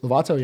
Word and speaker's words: Lovato: Bare Knuckle Boxing, Renato Lovato. Is that how you pronounce his name? Do you know who that guy is? – Lovato, yeah.